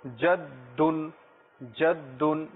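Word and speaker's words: জাদ্দুন জাদ্দুন (جَدٌّ): এখানেও যেখানে মায়ের ক্ষেত্রে যখন উম্মুন এর তাসদিদ রয়েছে, এখানেও দেখুন ছোট বন্ধুরা দালের উপরে তাসদিদ রয়েছে, যেটাকে আমরা দুইবার উচ্চারণ করব, জাদ্দুন জাদ্দুন।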